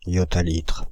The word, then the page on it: yottalitre: France (Île-de-France); /jɔ.ta.litʁ/